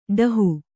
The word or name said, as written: ಡಾಹ